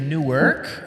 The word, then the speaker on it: Newark: Two syllables, not like the one in New Jersey. – Okay. Because the one in New Jersey is Newark, and it's kind of said in one syllable, but you guys have Newark, like Noah from the Bible. It's his second ark, like he blew up the first one.